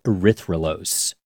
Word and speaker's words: erythrulose: erythrulose.